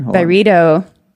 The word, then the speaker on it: Byredo: Ben Gorham, the founder of Byredo, or Byredo, how did he pronounce it again? – Byredo. That's the scoop. – Yeah. We've been pronouncing Byredo wrong. Byredo. It's Byredo, right? – I mean, that's what I heard.